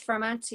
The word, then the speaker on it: Firmount: Am I pronouncing that right, Firmount? – It is Firmount, yeah.